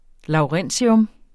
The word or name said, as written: LAWRENCIUM